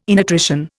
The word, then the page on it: innutrition: Indian